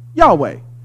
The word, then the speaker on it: Yahweh: Now, YHWH is usually pronounced in English Yahweh.